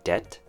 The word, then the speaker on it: debt: With this word, you don't pronounce the B sound. It's a silent B, so it's simply D-E-T, debt.